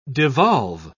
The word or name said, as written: devolve